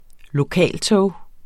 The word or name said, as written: LOKALTOGET